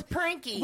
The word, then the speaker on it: Prinky: She was probably Princy, and you just pronounced it. – No, it was Prinky. – With a K? – Yeah, with a K.